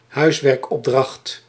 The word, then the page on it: huiswerkopdracht: Dutch